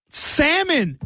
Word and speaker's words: salmon: Salmon. Hey, there's no L pronunciation in salmon.